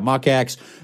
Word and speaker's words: Mach X: like the Saucony Kinvara Pro or the Hoka Mach X. Am I saying that right? Yeah, Mach X, the Adidas Boston 12.